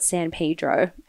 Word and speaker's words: San Pedro: So he turned up to board a charter fishing boat called the Freedom it's spelled San Pedro, but I read that locals call it San Pedro.